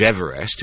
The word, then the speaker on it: Everest: Its leaders included George Everest, who I understand is now to be pronounced George Everest, after whom the world's highest peak is named.